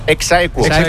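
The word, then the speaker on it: ex aequo: Sería Exaequo con Eri, por favor, sería con Eri. – No. Sí. ¿Cómo se dice? – Exaequo. – Así se dice.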